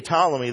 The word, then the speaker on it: Ptolemy: Oh, don't say the P, Ptolemy.